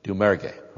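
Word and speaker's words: Doumergue: But Dommerger, how do you pronounce that name? – Dommerger. – Okay, I never would have thought of it pronouncing it that way.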